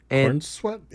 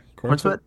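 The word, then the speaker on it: Corenswet: But yeah, so David Cornsweet, I think that's how you say his name. Forgive me if I'm saying it wrong. – Cornsweet, yeah. – Cornsweet, right.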